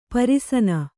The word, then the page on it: ಪರಸನೆ: Kannada